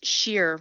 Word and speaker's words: Scherer: It's Scheer, rhymes with beer.